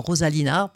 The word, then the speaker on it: Rosalina: Alors, on peut nous retrouver sur la page Facebook de notre groupe Rosalina, dans Rosalina, je prononce en français pour l'écriture.